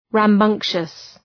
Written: rambunctious

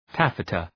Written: taffeta

